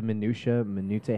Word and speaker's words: minutiae: There's not enough time to actually discuss the minutiae, how you pronounce that word? – Minutiae.